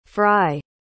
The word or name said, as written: frye